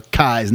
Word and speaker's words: Kies: You know, Kies, not Keys, whatever. And I was like, oh my, Kies like eyes.